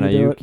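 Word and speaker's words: Aiyuk: I don't know how to pronounce the rookie Niners. Ayuk. – Brandon Ayuk. Yeah.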